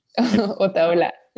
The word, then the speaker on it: Otaola: tell me if I'm pronouncing it wrong, Alex Otoloa. – Otaola, yeah.